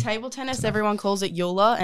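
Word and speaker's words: Joola: Yula? – Okay. See, right. There you go. – Yula's, Yula? That's how in table tennis, everyone calls it Yula.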